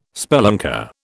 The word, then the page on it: spelunker: British English